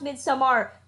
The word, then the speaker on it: Midsommar: And it is pronounced Midsommar. We were told at the press day that's how it's pronounced. Do not call it Midsommar. – It's definitely Midsommar.